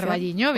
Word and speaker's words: O Carballiño: O Carballiño, no lo sé pronunciar. – O Carballiño, bien.